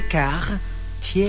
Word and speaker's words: Cartier: That's not how you say it. You have to say car. Tier.